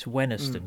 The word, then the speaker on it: Wenhaston: There was one called, it's spelt Wenhaston, but it's Wenhaston.